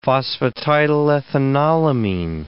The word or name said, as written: phosphatidylethanolamine